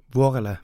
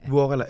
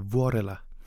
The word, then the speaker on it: Vuorela: Men nu vil jeg lige, før jeg siger det forkert en gang til, hvordan udtaler man dit efternavn? – Vuorela. – Vuorela. Lidt mere. – Vuorela. Eller på finsk siger de Vuorela.